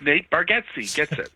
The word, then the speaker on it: Bargatze: It's Bargatze. – Why can I not say that? – Well, because it's spelled with two A's and an E, and it sounds like it's two E's and an A. I think of it this the way I figured out how to say it is it's like, do you remember Lethal Weapon 2? I think it was when they had Joe Pesci's character. – Yes. – His name was Leo Getz.